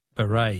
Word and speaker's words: beret: Words that end with a silent T. Beret is B-E-R-E-T.